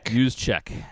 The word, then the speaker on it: Juszczyk: I see a guy that's like Kyle Juszczyk. I will pronounce that name correctly at some point. – Juszczyk.